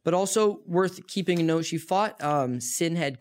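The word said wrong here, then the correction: Sinéad: Kavanaugh, right? – Sinead. – That's how you say it over there. Yeah.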